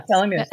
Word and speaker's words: telomeres: It's taking place at UC San Francisco, where they're looking at the, I don't know how you pronounce it, the telomeres? – Yeah, telomeres.